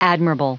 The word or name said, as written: admirable